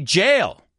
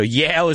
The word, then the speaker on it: Yale: OK, now be careful, because if you pronounce it wrong, you're going to say jail. And it's not the same thing. Jail and Yale. So Yale is a university and jail is where you go if you do something wrong.